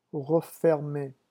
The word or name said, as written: refermer